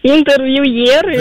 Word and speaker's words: интервьюеры: Данное слово пришло к нам из французского, в котором, как известно, ударение ставится на последний слог. Поэтому правильно говорить еще раз, как? – Интервьюеры.